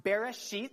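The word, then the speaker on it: Bereshit (בראשית): First of all, there's a word pronounced Bereshit. It's not a bad word. Bereshit. It's a Hebrew word, and it literally means what is first.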